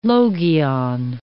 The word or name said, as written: logion